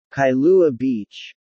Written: Kailua Beach

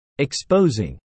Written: exposing

